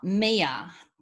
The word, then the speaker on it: Mia: Ah, Mia. – Okay. – That's how we pronounce Mia, M-I-A.